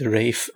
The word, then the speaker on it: Ralph: Alla uttalas inte Rafe.